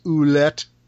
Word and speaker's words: Ouellette: A tag match against the amazing French Canadians, Jacques Rougeau and Carl Ouellette, who is not related to Maurice Ouellette. – Yeah. – How do you pronounce that? Do you know how that's really pronounced? – I think you nailed it.